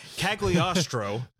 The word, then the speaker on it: Cagliostro: The Castle of Cagliari. I'm going to probably butcher the name. Cagliostro. Yeah. The Castle of Cagliostro.